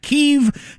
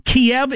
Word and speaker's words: Kyiv: From now on, I'm pronouncing it Kyiv because that's the way the Ukrainians pronounce it. Kiev is the Russian pronunciation.